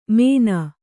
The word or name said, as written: ಮೇನ